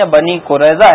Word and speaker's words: بنی قریظہ: مسجد نبوی بنی قریظہ کے باغ میں بنائی گئی. – بنی قریظہ تلفظ درست نہیں ہے، بنی قریظہ ہے، لیکن آپ کا یہ جواب غلط ہے.